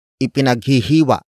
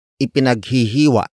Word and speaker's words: ipinaghihiwa: Word for word it's, ipinaghihiwa niya ang kanyang kapatid ng cake. Ipinaghihiwa niya ang kanyang kapatid ng cake. Ipinaghihiwa niya ang kanyang kapatid ng cake. The first word is ipinaghihiwa.